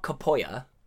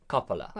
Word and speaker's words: Coppola: I mean, it's not Spanish. It's not Capoya. – No, that's what I'm saying with Italian. Yeah. Is it Italian? – Yeah, Coppola.